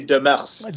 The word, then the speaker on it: Demers: And so when you mispronounce my name, remember I'm French. It should be Demers.